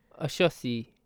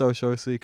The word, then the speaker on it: Oxossi: It's spelled like O-X-O-S-S-I. – Oshosi. – Yeah. – I big ups you, you're from Brazil. – I think it's Oshosi, because I heard a guy talk, I heard it on radio once, and it was like, yeah.